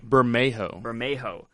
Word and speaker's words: Bermejo: We see Azzarello, and is it Bermoge? I don't know how to say the last name. – Bermejo.